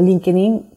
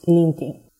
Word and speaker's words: LinkedIn: tenemos Instagram, Twitter, Pinterest, YouTube, eh, LinkedIn, que bueno, en español y todo el mundo lo, pronuncia como LinkedIn, pero es LinkedIn.